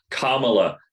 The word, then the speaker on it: Kamala: I, I I literally cannot pronounce her first name correctly, Kamala. no matter how much. – Kamala.